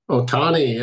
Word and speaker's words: Ohtani: Ohtani, yeah, Dodgers.